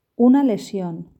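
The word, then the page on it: una lesion: Spanish (Spain)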